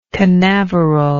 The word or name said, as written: canaveral